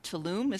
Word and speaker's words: Tulum: She says, oh gosh, I might would say Budapest, but I adore New York and my family loves Tulum. Is that how it's pronounced? – Yeah.